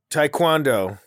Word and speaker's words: taekwondo: Will you say the word for me, please? – Taekwondo. – There we go. – Well, why do you say it like that? – Because that's how it's said.